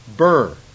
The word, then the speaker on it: bier: And the newer dictionary said it's pronounced burr. So whichever one you want, you go ahead and help yourself. But I'm gonna pronounce it bear. To me, it looks like beer, B-I-E-R.